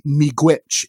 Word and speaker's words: Miigwech: It's pronounced Miigwech, and that means thank you.